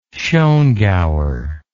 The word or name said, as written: Schongauer